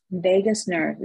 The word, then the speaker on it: vagus: And I wanted to make sure I'm pronouncing this right because I wrote it down. It's called a vagus nerve because I wanted to Google it. Mm-hmm. – Yes, vagus nerve, V-A-G-U-S. – V-A-G-U-S.